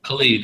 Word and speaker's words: Khalid: Is that how you pronounce his name? – Khalid, yeah.